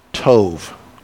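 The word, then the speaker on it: tov (טוב): tov. Tov. It's spelled T-O-B like in English, but the B in Hebrew kind of says V, so everybody try your hand at tov.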